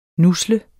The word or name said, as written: nusle